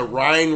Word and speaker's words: Rhine: Maybe it was the Rhine River. Am I even saying that right? Uh, yeah, it was Patton pissing in the Rhine, but anyway.